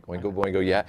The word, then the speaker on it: Oingo Boingo: Oingo Boingo was the band that kind of changed music for forever. – Did I say that right? – Yeah, Oingo Boingo,